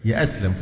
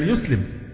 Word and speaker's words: يسلم: يأسلم فلان عايز يأسلم، هي الفعل يسلم، إنما يأسلم دي كلمة من اختراع الناس في هذا العصر من الركاكة